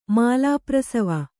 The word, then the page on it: ಮಾಲಾಪ್ರಸವ: kannada